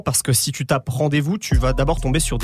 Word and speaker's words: RNDZ-VS: Et attention, le groupe s'appelle Rendez-vous, mais ça s'écrit RNDZ-VS.